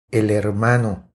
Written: El hermano

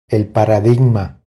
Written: El paradigma

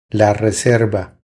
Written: La reserva

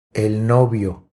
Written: El novio

